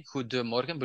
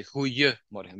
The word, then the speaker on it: goedemorgen: So, and for example, in a lot of words, you have a D in the middle in an adjective, but in a colloquial language, we kick that D out. So you have good morning, goedemorgen. But when we speak, we say goeiemorgen. We kick the D out in pronunciation.